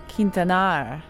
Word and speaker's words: Hector Quintanar: Hector Quintanar. Hector Quintanar. H-E-C-T-O-R. The H is completely silent. Last name, Quintanar. Okay, emphasis on the last syllable. Quintanar. Oh, roll your R slightly. Quintanar.